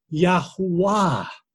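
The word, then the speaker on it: Yahweh: Greek transcriptions also indicate that YHWH should be pronounced Yahuwah.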